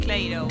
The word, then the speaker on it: Clairo: Cleiro. Cleiro. ¿Así se pronuncia? – Clairo. – Clairo, Clairo.